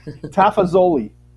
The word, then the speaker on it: Tafazzoli: Tafazzoli.